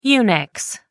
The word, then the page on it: unix: American English